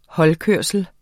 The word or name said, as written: holdkørsel